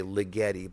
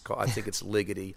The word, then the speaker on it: Ligeti: A lot of people say Ligeti, but I'm pretty sure now. I've said it wrong for years. I think it's Ligeti. Anyway, he has a great set of piano pieces, his etudes, and I love those.